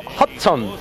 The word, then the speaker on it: Hudson: Bien, estamos retornando nosotros desde la ciudad de Nueva York, detrás del río Hudson.